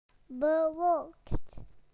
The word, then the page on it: cloudy: British English